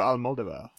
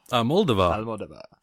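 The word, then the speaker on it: Almodóvar: So this is Pedro Almodovar. – Almodovar. Is it Almodovar? I've been calling it Almodovar.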